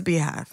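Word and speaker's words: Beyhive: You know it's spelled B-E-Y. – I know, but it's Beehive.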